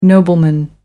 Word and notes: nobleman: American English

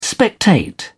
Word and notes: spectate: British English

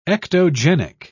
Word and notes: ectogenic: British English

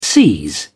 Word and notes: seize: British English

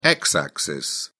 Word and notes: x-axis: British English

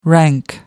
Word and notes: rank: American English

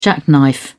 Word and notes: jackknife: British English

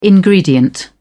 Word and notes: ingredient: British English

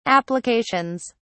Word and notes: applications: British English

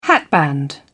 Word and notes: hatband: British English

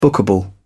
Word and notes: bookable: British English

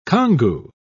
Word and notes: congou: British English

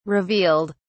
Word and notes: revealed: British English

